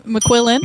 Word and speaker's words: McQuillen: Am I pronouncing that right? – McQuillen. – McQuillen. Okay. There you go.